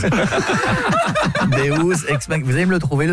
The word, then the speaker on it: deus ex machina: Deus ex machina. – Parce que deux, j'aurais dit que c'était deux ex que t'appelles machine. – Non, ça se prononce deus ex machina.